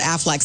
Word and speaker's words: Aflac: It rhymes with quack. – The idea has been floating around for years, and the executives over at Aflac say, we know it's everybody's idea.